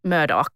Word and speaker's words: Murdaugh: And it's pronounced Murdaugh. I looked it up.